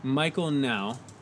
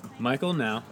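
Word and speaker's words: Michael Nau: Yeah, N-A-U is how you spell that. – N-O-N-A-U. – No, not O-N-A-U. N-A-U. – Oh, N-A-U. – Yeah. – Okay. – Oh. – No. Wait. – No, I'm confused. – Not no, now.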